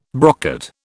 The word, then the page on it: brocket: British English